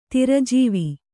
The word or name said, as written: ತಿರಜೀವಿ